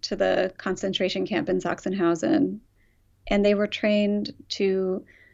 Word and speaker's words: Sachsenhausen: And then there's this whole backstory about how the cows are from, forgive the pronunciation, is it Sachsenhausen? – Sachsenhausen.